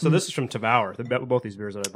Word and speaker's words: Tavour: I've been pronouncing that wrong this whole time. Tavour? – I'm not educated on this. I always say Tovar.